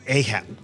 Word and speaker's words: Ahab: Ahab. That's right. The pronunciation in English is different. Ahab. But in Spanish, it's Acab.